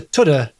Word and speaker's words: Tudhoe: Or to pronounce that correctly, come with me to Tudhoe near Spennymoor.